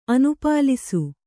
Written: ಅನುಪಾಲಿಸು

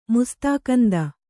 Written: ಮುಸ್ತಾಕಂದ

